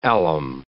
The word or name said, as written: alum